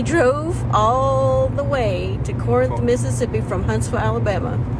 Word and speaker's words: Corinth: We are about 20 miles out of Corinth.